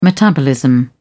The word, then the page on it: metabolism: British English